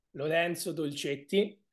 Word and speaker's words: Lorenzo Dolcetti: Lorenzo Dolcetti. – Again, a little bit different to how Stu pronounced it.